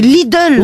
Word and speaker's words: Lidl: Il n'y a pas de O, c'est Lidl.